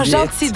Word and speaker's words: Jeanty: Ah, Gentil, donc on dit ça. – Ouais, s'écrit J-E-A-N-T-Y.